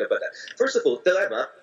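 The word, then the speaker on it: Thelema: And so the pronunciation is Telema, and it's a Greek word that means will, like the will to do things, the will to establish things, to manifest things.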